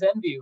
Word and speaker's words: Envu: Our guest is with Envu. Am I saying that right? – Yeah, it is. It is Envu.